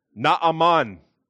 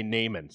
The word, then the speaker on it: Naaman: We've got another word today with a double vowel, Naaman, Naaman. But I'm going to do my children a favor this morning, and we're just going to say Naaman.